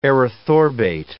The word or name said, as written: erythorbate